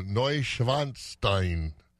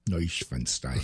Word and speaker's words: Neuschwanstein: The young lady called and said the correct pronunciation. I said, I appreciate that because I can pronounce things wrong awfully easy out there. so, yeah. uh, Neuschwanstein Neuschwanstein. So that's uh, the castle we're going to.